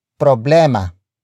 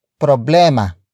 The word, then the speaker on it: problema: And that is problema. Problema. Problema. Problema is spelled as P-R-O-B-L-E-M-A.